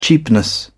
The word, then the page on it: cheapness: British English